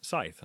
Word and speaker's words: scythe: I keep mispronouncing that. Like I realized, I was like, you know, Sith doesn't sound right. I should look this up. And I realized it was Scythe. I was like, right. Okay, I've been pronouncing it wrong this whole time. – See, I've always pronounced it as a Sai, mm-hmm. not Scythe. No, you don't put the TH on there, but you know, what the hell do I know? – I mean, that's what Google told me.